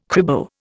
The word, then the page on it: cribo: Indian